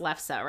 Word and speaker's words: lefse: Yeah, I think it's pronounced Lefse, right? – Yes.